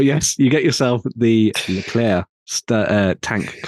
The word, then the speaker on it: Leclerc: Leclerc. Leclerc. – Leclerc. – Leclerc. – It's like a think of it like a chocolate eclair.